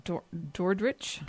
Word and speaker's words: Dordrecht: I'm going to say this wrong, Dordrecht. – Yeah, Dordrecht. – Dordrecht.